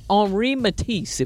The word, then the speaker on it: Henri Matisse: Henri Matisse, if we say it properly.